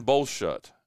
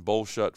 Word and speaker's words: Boschult: Minor League Baseball return, and the story was by, because I like to give credit to reporters that do it, Christian Bolshut. I think that's how you say his name, B-O-S-C-H-U-L-T, Christian Bolshut from the Post and Courier in Spartanburg, South Carolina.